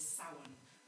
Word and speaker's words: Samhain: Well, Halloween is based around the original pagan festival of Samhain, which is spelled Samhain, just to be confusing.